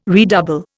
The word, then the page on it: redouble: Indian